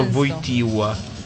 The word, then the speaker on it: Wojtyła: Lo riconosco perché c'è proprio quella L, che è la stessa L che trovavi nella parola Wojtyła e che ti costringeva a pronunciarlo Wojtyła.